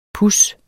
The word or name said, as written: puds